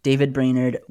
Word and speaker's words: David Brainerd: So of these seven folks, right, we have Martin Luther, Hannah Allen, David Brainerd. Did I say that right? – You did. – David Brainerd,